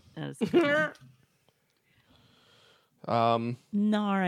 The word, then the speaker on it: Gnar: Gnar. – Gnar. – For the wiki, it's spelled G N A R. – I just spelled it that Gnar. – Way. High five. Nice. Like gnarled.